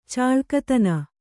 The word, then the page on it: ಚುಲಕತನ: culakatana